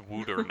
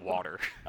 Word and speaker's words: water: I found a photo of Gritty that said that of him holding a sign saying it's pronounced wooter, not water.